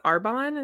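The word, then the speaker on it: Arbonne: She's Arbon. Is that how you pronounce it? – Yes.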